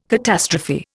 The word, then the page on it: catastrophe: Indian